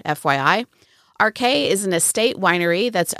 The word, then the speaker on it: Arche: My guest today is Grayson Davies, winemaker at Arche Winery in St. Joe in far, far north Texas. Arche is spelled A-R-C-H-E, but it's pronounced like the two letters R and K.